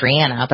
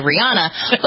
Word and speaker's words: Rihanna: Well, look at Rihanna, whose name is Rihanna, but a lot of right. – people say Rihanna.